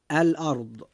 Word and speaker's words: الأرض: أن كلمة الأرض فيها حرف الراء جاء ساكنا، وقد جاء ما قبله مفتوحا، فيجب أن تفخم الراء عند نطقنا لكلمة الأرض هكذا. – الأرض.